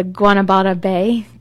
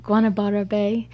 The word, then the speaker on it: Guanabara Bay: And of the coastline, and the bay where the water comes in, they call it the Guanabara Bay. Guanabara Bay. I really like the way that rolled off the tongue, although we had to practice it to say it correctly.